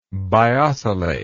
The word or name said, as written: biocellate